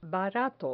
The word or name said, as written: barato